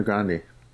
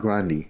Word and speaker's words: Grande: You know, we've all been pronouncing that shit wrong. – Cardi B? – No, what? No. Ariana Grande. It's Ariana Grande. That's Grande? How it's pronounced. Yeah. – Who said that? Her? – She. – Really? – She. That's how she pronounces her last name.